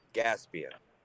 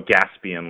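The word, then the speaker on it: Gaspain: Gaspian. – Oh, Gaspian, like gasoline. – Correct.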